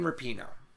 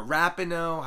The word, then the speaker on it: Rapinoe: My beta of the week, Megan Rapinoe. Megan Rapinoe. – Or Rapinoe, however you want to pronounce it.